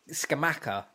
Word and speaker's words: Scamacca: But in West Ham, Scamacca, is that how you pronounce it? – Yeah, they've bought him.